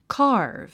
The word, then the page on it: carve: kɑ’ː r v; カーブ